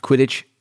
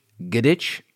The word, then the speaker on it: Quidditch: But maybe I've already shared this story, but the first Harry Potter was read to me by my godmother and she just pronounced Quidditch Gidditch. So for years I was like Gidditch, the Gidditch, wizard right?